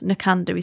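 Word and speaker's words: Knockando: It's Nakandu Wool Mill, Knock and O, it's spelt. Nakandu is how you say it.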